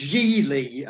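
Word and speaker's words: Gigli: It's pronounced Geely. And Ben Affleck makes a point in the film. This is one of the things that's so wrong with that movie. He's got this big thing in the film where he keeps telling people that my name's Geely. It rhymes with really. And I'm thinking, no, it doesn't. Geely does not rhyme with really, Ben.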